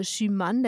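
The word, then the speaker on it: Schuman: Und wenn ich ganz am Anfang Bezug genommen habe auf Robert Schumann oder Sie haben es französisch ausgesprochen, äh, Schumann, der war französischer Außenminister.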